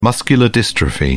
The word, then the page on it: muscular dystrophy: British English